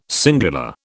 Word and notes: singular: British English

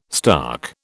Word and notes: stark: British English

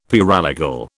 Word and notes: paralegal: British English

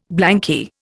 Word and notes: blanky: Indian

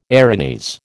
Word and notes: Erinys: American English

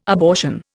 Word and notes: abortion: Indian

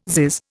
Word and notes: zizz: Indian

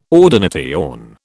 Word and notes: ordination: British English

